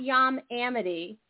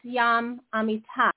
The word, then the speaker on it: Yam Amitay: His name is not Yam Amity. It's Yam Amitai.